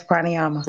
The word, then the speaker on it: pranayama: She's also an advanced yogi specializing in, correct me if I'm saying this wrong, pranayama? – Yes, pranayama.